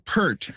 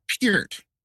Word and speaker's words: Peart: I've been saying Neil Peart, as in Pearl. – Yeah. – It's Peart. Yeah.